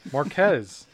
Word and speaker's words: Marques: Marques. That's how you say it.